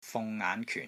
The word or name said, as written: FUNG6 NGAAN5 KYUN4